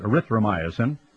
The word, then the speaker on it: erythromycin: And after 40 years of millions of Americans slathering ourselves with erythromycin, I guess it's called, or erythromycin is the way to pronounce it, erythromycin, and penicillin and other wonder drugs, we have lost much of our natural immunity.